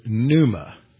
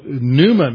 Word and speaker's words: pneuma: And every time you see the word Spirit, it's a translation of the Greek word pneuma. Pneuma has numerous meanings. It's spelled P-N-E-U-M-A, like you'd spell a pneumatic or pneumonia.